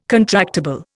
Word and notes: contractible: Indian

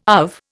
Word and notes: AV: Indian

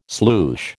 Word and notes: sloosh: American English

